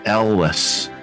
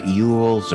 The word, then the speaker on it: Elwes: It's uh, Elwes, Elwes, Elwes, Elwes. Yeah, Elwes. Okay. Uh, it's not Ewells or Eels or anything like that. It's it's it's definitely pronounced um, Elwes.